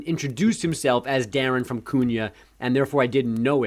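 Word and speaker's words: Kuna: I believe it's Cunha. – Cunha. – Probably still getting it wrong. It's not Cunha, like it's spelled.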